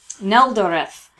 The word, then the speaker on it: Neldoreth: Neldoreth. N-E-L-D-O-R. E-T-H. – Okay. – Exactly how it sort of sounds.